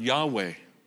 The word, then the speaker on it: Yahweh: It's unpronounceable. Add some vowels and you get the word Yahweh.